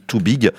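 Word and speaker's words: 2BYG: Parce que ça s'écrit avec le chiffre 2 et B-Y-G. Ouais. Alors, on se dit Too Big.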